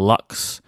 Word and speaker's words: Lx: It's LX, but it's pronounced Lux or Lux. I'm going to go with Lux because it has an E at the end from the pronunciation mm-hmm.